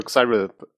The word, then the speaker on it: Cyril: Äh, Cyril spricht sich der Name richtig aus, so ein bisschen Englisch.